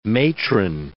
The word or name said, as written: matron